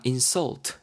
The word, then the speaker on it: insult: 여러분 insult가 지금처럼 발음이 insult 강세가 앞에 들어가면요 명사로 지금 활용이 되는 거고 모욕이란 뜻입니다. 그러 그러니까 무슨 그런 모욕을 하냐라는 거죠. 근데 여러분 같은 단어인데요. 여러분가 insult 이렇게 강세가 뒤에 들어가잖아요.